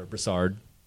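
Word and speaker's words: Broussard: Brassard, I'm hoping I've been saying that correctly, Louisiana. – Yeah, so go with it.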